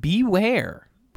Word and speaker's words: Bewear: Beware. Which is spelled B-E-W-E-A-R. But it's homophonic with beware, the word.